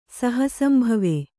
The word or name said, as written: ಸಹಸಂಭವೆ